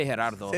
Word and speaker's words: Gerardo: And it's only been in the last, like, 10 years or so where I'm kind of like, no, you're going to say Gerardo.